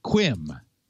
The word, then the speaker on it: cwm: C-W-M. – No one knows how to pronounce that even. – There's no vowel. – It's C-W-M. Like Welsh. – Yes, it is. I looked it up. – It's Welsh.